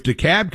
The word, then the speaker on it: DeKalb: And if they want to be called, if Houston, Georgia wants to be called Houston, Georgia, if DeKalb County wants to have a silent L,